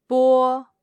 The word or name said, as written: bo1